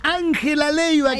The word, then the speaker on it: Ángela Leiva: La Ángela Leiva que va a estar... – Lo pronuncié como la cara mía. Vamos de vuelta, si le parece, si me lo permite. Amigas, ¿por qué? Ángela Leiva, que va a estar el próximo domingo en el festejo del Día del Niño con entrada libre y gratuita.